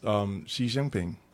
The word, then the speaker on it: Xi Jinping: Even China's Xi Jinping.